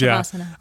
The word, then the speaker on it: savasana: Corpse pose. Shavasana. Yeah. I said it wrong.